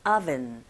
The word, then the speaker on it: oven: oven 하실 때도 oven이 아니라 윗니로 아랫입술을 살짝 긁어주셔야 되겠죠. oven, oven. 갑니다.